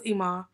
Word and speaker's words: Iman: Am I saying his name right, y'all? – It's Iman. – Iman Shumpert, right? – Iman.